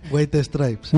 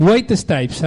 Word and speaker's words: White Stripes: White Stripes. – White Stripes.